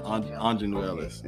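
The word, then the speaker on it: Aunjanue Ellis: Okay, yeah, Anjanue Ellis.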